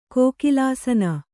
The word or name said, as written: ಕೋಕಿಲಾಸನ